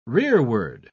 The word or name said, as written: rearward